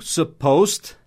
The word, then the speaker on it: supposed: S-U-P-P-O-S-E-D. Supposed. Mas esse E-D não vai ter a pronúncia supposed. Supposed, tá? Vai ser um som de T. Supposed. Supposed. Tem toda uma razão aí da pronúncia do passado em inglês. Mas a gente não vai entrar nisso agora. Mas é som de T.